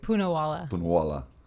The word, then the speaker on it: Poonawala: Poonawala.